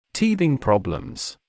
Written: teething problems

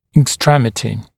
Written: extremity

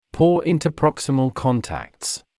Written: poor interproximal contacts

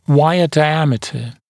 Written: wire diameter

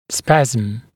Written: spasm

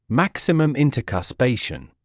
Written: maximum intercuspation

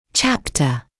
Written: chapter

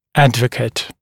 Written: advocate

